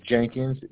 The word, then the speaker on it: Jenkins: First name is spelled E-R-I-N. – All right. And your last name? – Last name is Jenkins, J-E-N-K-I-N-S.